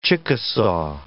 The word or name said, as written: Chickasaw